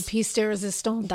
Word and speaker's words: pièce de résistance: Now, here is the, how do you say it again? Pièce de la résistance. – The pièce de résistance. – That, thank you. I added too many, like, laws.